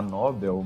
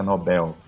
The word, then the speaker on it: Nobel: Bom, acho que a gente fica inclinado a falar Nobel, mas pela pronúncia, né, por, por ser em outro idioma, a pronúncia correta é Nobel.